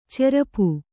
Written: ಚರಪು

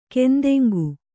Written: ಕೆಂದೆಂಗು

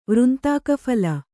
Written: ವೃಂತಾಕಫಲ

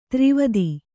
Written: ದುರ್ವಾದ

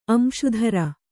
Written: ಅಂಶುಧರ

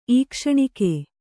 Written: ಈಕ್ಷಣಿಕೆ

